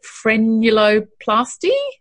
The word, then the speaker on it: frenuloplasty: So today we're going to talk about tell me if I say this right. Is it frenuloplasty? – Yeah, that's the way.